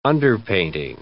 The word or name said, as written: underpainting